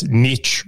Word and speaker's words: niche: And he said to me, he goes, like, you realize niche rhymes with rich for a reason, yeah?